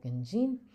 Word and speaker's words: Genjin: Another example is Genjin, which means to track, where like the stress is on that second syllable, Genjin.